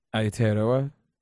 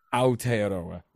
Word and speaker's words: Aotearoa: How do you say it? – Aotearoa. – Aotearoa, right. – Yeah.